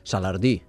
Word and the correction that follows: Salardú: Salardú. – Bé, ho he pronunciat malament.